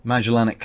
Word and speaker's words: Magellanic: So Magellanic clouds. That's how it's said.